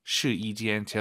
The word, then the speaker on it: 试衣间: 숫자 1이 아니라 여기서는 YI 발음 시이 아니고 시이 시이间. 제가 이렇게 왜 말씀을 드리냐면 한국인 초급자분들이 가장 어려워하는 게 사성담의 일성이에요. 따지아 이 발음을 따지아 막 사성이 일성이 돼버려요. 네, 그래서 시이间 확실하게 시이 이를 확 올려서 발음하셔야 됩니다.